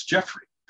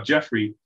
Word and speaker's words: Jeffrey: And so I figured, I'll become Gramps Jeffrey. And that became my pen name. Now, what's interesting about Jeffrey, spelled J-E-F-F-R-E-Y, which is one way to spell it.